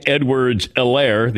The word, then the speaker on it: Edwards-Helaire: Clyde Edwards-Helaire, the H is silent, goes for 138 and a touchdown.